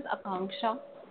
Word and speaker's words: Akanksha: Hi, this is Akanksha. – Okay, how do you spell that? – A-K-A-N-K-S-H-A.